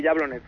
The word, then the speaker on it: Jablonec: Oye, tú juegas en la liga, en la Gambrinus League esta, en el Jablonec, ¿no? – Sí, bueno, ahora se llama Sinot Liga, pero sí. – Sinot Liga. Oye, se pronuncia Jablonec, ¿no? – Sí, Jablonec.